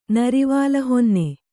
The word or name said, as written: ನರಿವಾಲಹೊನ್ನೆ